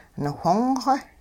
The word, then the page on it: niwhong-xw: hupa